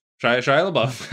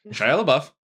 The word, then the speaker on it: Shia LaBeouf: Shia Buff. Shia LaBeouf. I can't even say his name. Shia LaBeouf.